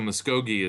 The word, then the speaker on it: Muskogee: So, so Muskogee is how you say it.